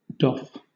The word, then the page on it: doff: British English